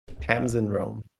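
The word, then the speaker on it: Tamsin Roame: We have Tasman Rome May. Is that how you pronounce it? – Tamsin Rome.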